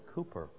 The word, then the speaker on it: Cowper: William Cowper.